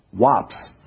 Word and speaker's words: waps: Centuries ago, the old English word bird was spelled B-R-Y-D and pronounced brid, while wasp was spelled W-A-P-S and pronounced wops.